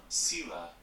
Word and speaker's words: Selah: It's pronounced Selah. I said it like that at first, but then everyone was saying it like Selah, and I was like, I'm saying it wrong.